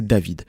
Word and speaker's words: D4VD: son nom de scène s'appelle D4VD, mais de façon machinale, on aura tendance à le prononcer David.